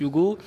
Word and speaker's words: HUGO: Hugo, parce qu'on le prononce Hugo, c'est le H de harceler, parce qu'on s'adresse en premier au harcelé. C'est le U de you à l'anglaise et le go de on avance, en fait.